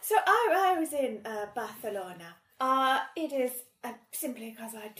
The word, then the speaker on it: Barcelona: And, you know, if one has been to Barcelona, which I will keep saying in that accent, as I do think it is important... – That is correct pronunciation.